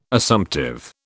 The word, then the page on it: Assumptive: British English